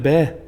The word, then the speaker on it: BAE: B-A-E. – Ich glaube, das wird bäh ausgesprochen. Also wie, also wie Baby.